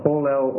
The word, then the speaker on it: polel: Yeah, no, polel or polal. The only difference is the last syllable. Polel, el, or polal, al. See the page 254 there? Polel or polal.